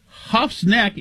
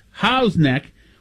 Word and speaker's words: Howe's Neck: 617 says, Huff's neck is pronounced Howe's neck, or as the locals say, the neck.